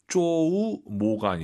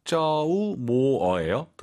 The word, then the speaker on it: 周末: 주말 발음을 쪼우 모가 아니라 쪼우 모어예요.